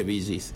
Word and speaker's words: Bee Gees: Ah, se pronuncia Bee Gees.